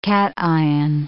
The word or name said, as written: cation